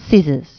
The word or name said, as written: scissors